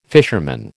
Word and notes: fisherman: American English

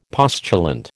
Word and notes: postulant: American English

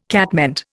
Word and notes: catmint: Indian

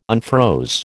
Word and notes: unfroze: American English

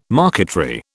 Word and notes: marquetry: British English

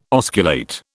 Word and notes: osculate: British English

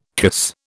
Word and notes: cuisse: British English

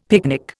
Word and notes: picnic: Indian

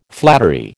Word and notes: flattery: American English

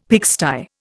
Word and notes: pigsty: Indian

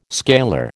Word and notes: scalar: American English

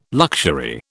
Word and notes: luxury: British English

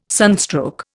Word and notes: sunstroke: Indian

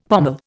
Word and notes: pommel: Indian

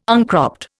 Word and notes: uncropped: Indian

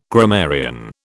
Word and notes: grammarian: British English

